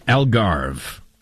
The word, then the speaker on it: Algarve: Algarve. – Algarve coastline, and so much more.